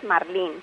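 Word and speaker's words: marlín: Sí, efectivamente, la pronunciación correcta es marlin. – Marlin. Entonces, con énfasis en la última sílaba, y ortográficamente, como termina en n, se acentúa en la i, marlin.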